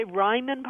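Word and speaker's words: Reimann: And we've, like I said, at our village center, we've always had a lot of Ryman. Did you say Ryman Publications? – Yeah, Ryman Publications. – How do you spell that? – R-E-I-M-A-N-N, I believe.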